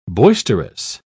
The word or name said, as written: boisterous